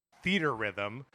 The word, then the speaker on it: Theatrhythm: I want to stop us at the pronunciation of Theater Rhythm or Theatrhythm. The announcer in the direct said Theater Rhythm, which I guess makes sense.